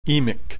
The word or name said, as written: emic